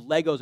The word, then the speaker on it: Lego: Jim Gaffigan said that hell is not going to be full of fire, just going to be full of Legos, and you're not going to get any shoes. That's all hell needs to be. Lego. I'm sorry, I was pronouncing that incorrect. It's not Legos. It's Lego. Sorry.